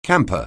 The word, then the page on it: camper: anglais